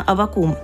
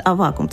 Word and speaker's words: Аввакум: примерно 400 лет назад родился протопоп Авакум или Авакум, но в старообрядческой традиции принято ударение ставить на Авакум, поэтому я буду использовать такое оригинальное, скажем так, произношение его имени. А в светской православной традиции, да, его называют Авакум, чтобы мы не путались.